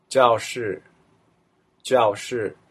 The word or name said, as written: jiaoshi